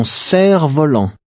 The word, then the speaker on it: cerf-volant: The F in the fourth word is silent. – Ton ballon, ton cerf-volant.